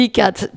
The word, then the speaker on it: IQADH: Donc pour ceux qui veulent en savoir plus, ICAD, tu dis ICAD, bah, effectivement ça s'écrit I-Q-A-D-H.